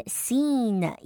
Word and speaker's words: scene: And scene, 一定要拉长 I 的音哦。Startle or, 这边的 startle, 一定要舌头往上让我听到 L 的音哦。Startle or, 一定要分开来念会比较清楚一点。Startle or distract fish and other potential predators.